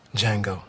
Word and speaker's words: Django: What's your name? – Django. – The D is silent.